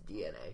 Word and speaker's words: DeNA: So unless you've been living under a rock, you're probably aware that Nintendo is now going to be teaming up with DNA, spelled D-E-N-A, but pronounced DNA, to create...